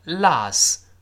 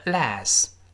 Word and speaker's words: Las: 当然，这个 Las 还有一个发音，有人也读成 Las Vegas，那个 Las 或者 Las 两种发音都对，发啊发哎都对，但口语中发啊的人更多一些。